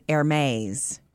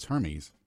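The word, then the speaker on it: Hermès: I thought it looks like Hermes. You've seen that, but I've always said Hermes. – No, it's Hermes. – It's not Hermes.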